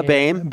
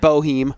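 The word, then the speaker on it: Boehm: One other mention that these Cardinals actually have solidified their right guard position as well as a how do you say Evan's last name? – Bame. – Bame. All right. Not how it's spelled at all. – It's not Boheme.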